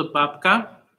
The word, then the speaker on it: babka: This is a babka, Polish babka.